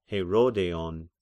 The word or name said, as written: Herodeion